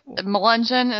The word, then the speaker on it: Melungeon: Melungeon, is that how it M-E-L-U-N-G-E-O-N? – Yeah, that's them.